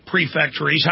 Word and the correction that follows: prefecture: How do you pronounce them properly? – It's prefecture.